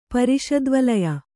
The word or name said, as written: ಪರಿಷದ್ವಲಯ